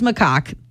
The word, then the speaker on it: macaque: So he's a snow monkey, also known as a Japanese macaque. That's how you say it. I Googled it. I confirmed. I know there are other people who say it differently, but that is what the internet told me to say.